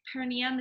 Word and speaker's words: perineum: Like the perineum, is that how you say it? – Mm-hmm.